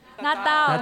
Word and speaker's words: Natal: Natal.